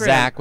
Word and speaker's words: Xaque: It's Xaque with an X, not Xaque. With the Z.